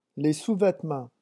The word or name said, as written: les sous-vetements